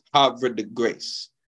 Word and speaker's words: Havre de Grace: And there's debate about how to pronounce it. But my wife and I recently went there a few months ago and we asked someone from there, and it's Havre de Grace.